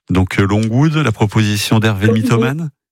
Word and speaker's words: Longwood: Longwood. On prononce comme ça, Longwood.